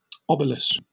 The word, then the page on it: obelus: British English